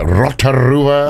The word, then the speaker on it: Rotorua: And I remember coming to New Zealand and doing the comedy festival, and then doing a little tour, going to places like Rotorua.